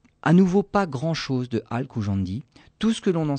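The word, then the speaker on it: al-Khujandi: sûr que c'est écorché, parce que le KH en arabe, c'est pas, c'est pas que. Hein. Donc on, on, va dire, on va le prononcer à l'occidental, Al-Koujandi.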